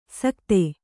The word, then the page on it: ಸಕ್ತೆ: sakte